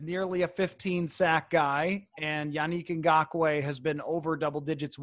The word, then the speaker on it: Yannick Ngakoue: It's Yannick Ngakoue, right? – Yannick, yes. – Yannick, yeah. Yannick Ngakoue.